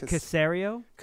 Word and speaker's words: Caserio: Nick Caserio.